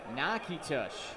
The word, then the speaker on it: Natchitoches: Northwestern State is in Nakitosh. 1-2 pitch. Swing and a bouncing ball foul. That's spelled N-A-T-C-H-I-T-O-C-H-E-S. Nakitosh. Not an easy one.